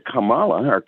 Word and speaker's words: Kamala: It's, just last week, if I could segue a little bit into Kamala, they pronounce her name Kamala. That's been an issue too. Take the name Pamela and put a K on it.